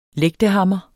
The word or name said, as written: lægtehammer